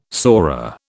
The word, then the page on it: sora: British English